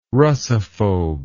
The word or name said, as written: russophobe